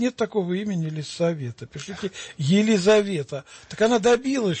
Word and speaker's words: Елисавета: Нельзя писать Елизавета. надо писать Елисавета, не Зе, а Се, потому что это имя произносится в церкви не через букву Зе, а через Се.